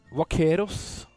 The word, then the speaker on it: vaquero: Vaqueros skrives det. Uttales vaquero, det jo da en hesteridende kvegeter som har sine røtter fra den iberiske halvøya.